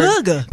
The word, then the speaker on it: hygge: Hygge. – But I've seen it, like in winter, all the lifestyle blogs write about that word. It's H-Y-G-G-E. – Hygge. – It's pronounced like that.